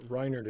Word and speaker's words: Reinhard: Reinhard is probably Reinhard. A little bit better. Reinhard isn't bad, though.